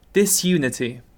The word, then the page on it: disunity: British English